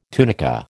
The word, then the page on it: tunica: American English